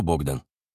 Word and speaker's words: Богдан: «Что, Богдан?» Мать называла его имя по-украински, с ударением на первый слог.